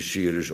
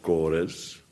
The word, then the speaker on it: Cyrus: Maar waarschijnlijk moet je Cyrus ook gewoon uitspreken als Kores. Want de C is een K en de Y is een O in sommige talen. En in andere talen een U.